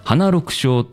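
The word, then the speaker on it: 花緑青: ちなみに 5 月なのでこの楽曲、まあ、どこかでお届けしようと思っておりましたが、花六章の窓辺からというタイトルなんですね。5 月は花六章の窓辺から。花六章ってご存知ですか？ね、花に緑に青と書いて花六章と読むんですけれども、まあ、色ですよね。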